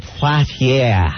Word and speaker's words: Poitier: Sidney Poitier. God, I can't say his name. – Sidney Poitier.